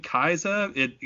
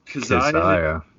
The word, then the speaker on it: Kezia: I've been saying Kaiza because it looks oddly Japanese to me. – Yeah. – But no. – I see the pronunciation on top of Wikipedia. I assume that's official from the band, huh? – Kazaya. Seems to be the agreed upon pronunciation of the album.